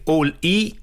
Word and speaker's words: Oli: Eh, si scrive Oli, si legge All I.